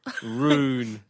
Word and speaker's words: rune: So just R double O N. – Yeah, that's how you say rune. – R-U-N-E. – Okay. I've been pronouncing that so wrongly my entire life. – So, and I don't even know where you got ruin because it's not an I.